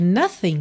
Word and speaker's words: nothing: E olha que interessante, nothing, na verdade, termina na letra G, mas o G não é pronunciado,